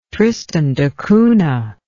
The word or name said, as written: tristan da cunha